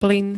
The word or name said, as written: plyn